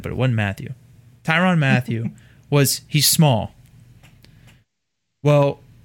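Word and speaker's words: Mathieu: Isn't it just pronounced Matthew, but everyone says Matthew? It's just Matthew, right? – Matthew, correct.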